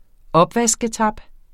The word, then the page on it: opvasketab: ˈʌbvasgəˌtɑb; -ˌtab